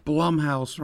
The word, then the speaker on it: Blumhouse: Blumhouse rhymes with dumb.